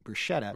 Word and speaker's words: bruschetta: is the fact that many people pronounce it with an anglicized pronunciation, bruschetta.